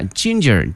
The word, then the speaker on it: ginger: 这个姜这个词同学们记一下 ginger G-I-N-G-E-R、哎、它的发音同学们一定要注意，第一个这个 G 呢发音成这个 ginger、啊、 ginger and garlic 还有大蒜。